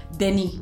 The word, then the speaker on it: Denis: Y eso, eso me gusta mucho de Denny porque, Denny, ya ahora sí le voy a decir Denny porque ya me, enseñó Herminio que así se pronuncia.